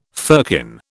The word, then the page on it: firkin: British English